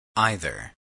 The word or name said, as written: either